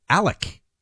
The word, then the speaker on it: Alec: I've gone from not knowing how to say the last name to apparently the first name, even though it's written Alex. He refers to himself, the defendant and the father of this family, as Alec.